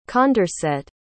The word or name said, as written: condorcet